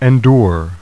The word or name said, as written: endure